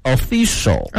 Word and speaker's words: official: 词汇畅谈，这单词的拼写是呢 official，注意一下后面 cial 呢，它的发音是一个翘舌音 official。